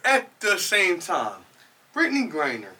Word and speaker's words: Griner: Griner, man. Like a heated hoagie.